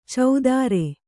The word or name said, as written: ಚೌದಾರೆ